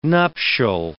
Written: nuptial

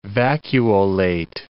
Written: vacuolate

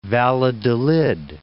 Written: Valladolid

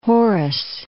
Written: Horace

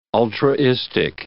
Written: ultraistic

